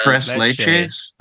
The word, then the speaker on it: tres leches: Is it leche cake? Is that how you say it? Where it's got the. – Tres leches. It means three milks.